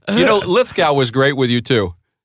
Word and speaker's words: Lithgow: It's Judd Apatow and John Lithgow, so it gets very confusing when they're running around with the same spellings at the end but different pronunciations. – Didn't know that. – But for some reason, Lithgow goes with go, and he was on with Paul Tompkins and Jimmy Pardo.